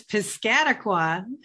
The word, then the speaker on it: Piscataqua: She lives on the seacoast in New Hampshire and enjoys the Piscataqua. I probably didn't say that right. You want to tell me? – It's Piscataqua.